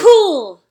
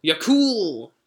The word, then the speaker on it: Yakul: Yakul. – Yakul.